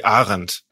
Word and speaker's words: Arendt: Yeah, I mean, she was of German descent, so probably Arendt. – Arendt, yeah, that.